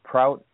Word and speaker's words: Prout: The guy, Prout, am I saying his name right? – Yeah.